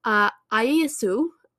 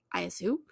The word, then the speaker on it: Iyasu: Now, the official succession as mandated by Zee's dad was that his grandson, Ayesu, I think that's how you pronounce that, it's A-Y-A-S-U, Ayesu,